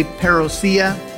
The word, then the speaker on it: parousia: P-A-R-O-U-S-I-A. Some people say parousia, others pronounce it parousia.